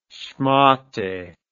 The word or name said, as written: shmatte